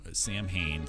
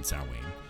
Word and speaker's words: Samhain: Samhain, Samhain, there's two different ways to pronounce the same thing.